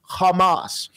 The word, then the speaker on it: Hamas: The word violence, it is the Hebrew word Hamas. We are going to see that Hamas is... – That is not misappropriated Hebrew. Wow. That is actually a correct word. He pronounced it okay.